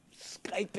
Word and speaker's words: Skype: He is going to skip in. – Skype in. – Skype. – The Y is sometimes pronounced like an I.